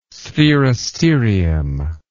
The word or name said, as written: sphaeristerium